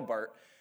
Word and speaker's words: Barth: And it's spelled like Barth. And this is how you kind of know if somebody knows like old theologians, if they can pronounce Barth correctly. So it's Karl Barth.